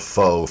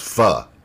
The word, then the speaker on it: pho: They had the pho noodles, and which I called pho forever because I'm a dumb white guy, but it's pronounced pho.